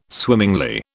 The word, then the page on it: swimmingly: British English